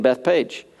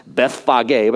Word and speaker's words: Bethphage: And look, Jesus is approaching Bethany in Bethpage. It's actually it's pronounced Bethphage, but I'm going with Bethpage.